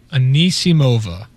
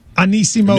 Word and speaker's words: Anisimova: Anisimova. – Anisimova.